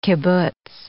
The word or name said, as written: kibbutz